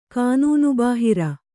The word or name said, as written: ಕಾನೂನುಬಾಹಿರ